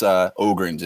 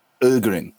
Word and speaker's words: Ögren: So to answer your question, my last name, well, American, it's Ögren, just say Ögren. But the pronunciation is Ögren, like It's the same thing as the island, Öland.